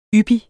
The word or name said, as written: yppig